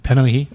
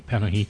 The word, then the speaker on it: Panahi: I guess you'd have Kiarostami and Panahi. Is that how you pronounce it? – Panahi. – Oh, excuse me. Panahi.